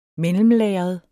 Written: mellemlagret

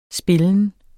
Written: spillen

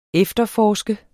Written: efterforske

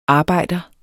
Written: arbejder

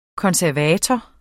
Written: konservator

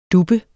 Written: duppe

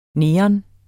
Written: neon

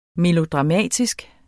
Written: melodramatisk

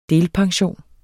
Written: delpension

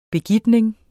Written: begitning

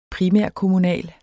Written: primærkommunal

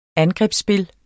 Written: angrebsspil

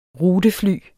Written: rutefly

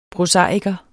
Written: prosaiker

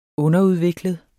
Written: underudviklet

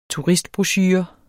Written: turistbrochure

